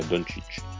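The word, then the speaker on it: Dončić: perché ho guardato un video dove era uno del, di Afragola che diceva Doncic, quindi la pronuncia corretta è Doncic.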